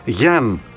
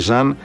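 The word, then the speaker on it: Yann: Επειδή ξέρεις ότι έκατσα και τ' άκουσα λίγο πώς θα προφέρουν οι ίδιοι, είναι Μπράνκου Μπάντιο ο Σενεγαλέζος και Γιάν, και όχι Ζαν, Γιάν Μοντέρο.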